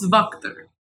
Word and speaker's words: Wachter: W-A-C-H-T-E-R. You might think it was Wachter, but the book says it is Wachter.